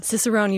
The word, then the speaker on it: cicerone: Your search and trial to become, I'm going to pronounce this wrong, Cicerone? – Cicerone, you've got it.